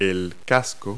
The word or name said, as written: helmet